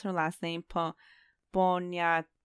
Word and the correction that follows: Poniatowska: It's Poniatowska.